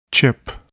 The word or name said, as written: chip